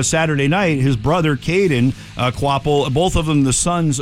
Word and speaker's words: Kvapil: It's spelled K-V-A-P-I-L, but it's pronounced Quapple. – Quapple. – Yeah. Quapple.